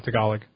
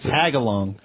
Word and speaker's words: Tagalog: How do you say it? – Tagalog. – Kvetch. – I thought it was Tagalong.